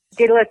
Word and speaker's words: Daedalus: Daedalus Maze.